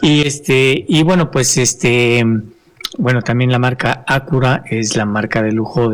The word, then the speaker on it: Acura: Así como Lexus es la marca de lujo de Toyota, y así como Acura o Acura, así porque el señor español se enoja de que, está mal pronunciado. Entonces, Acura.